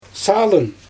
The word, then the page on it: salyn: Xyzyl